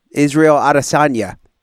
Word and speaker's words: Israel Adesanya: Stylebender, Israel Adesanya. Did I say his name correctly, Brandon? – Yeah, that's correct.